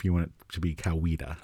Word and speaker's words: Coweta: So just because I've lived down there, I know that they pronounce it Coweta.